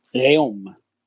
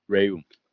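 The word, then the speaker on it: Reaume: It's R-E-A-U-M-E. – Raum. – Raum. Okay, I was going to say like room or something, so very bad.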